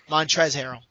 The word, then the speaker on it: Montrezl Harrell: Montrezl Harrell. The L is silent.